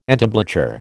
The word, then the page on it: entablature: American English